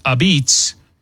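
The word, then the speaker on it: apizza: They're calling it a pizza, pronounced a beets.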